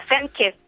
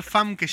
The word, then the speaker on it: Famke: Femke. – Para mí siempre va a ser Famke Janssen, pero seguro lo digo mal. – Para mí es con 12 igual, es Femke Janssen, me parece.